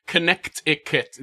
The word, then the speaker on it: Connecticut: Hey, you pronounce Connecticut. It's Connecticut. There's a T in there for some reason.